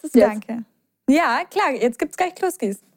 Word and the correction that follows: Kluski: Habe ich richtig ausgesprochen? Kloski? – Kloski. Kluski mit U.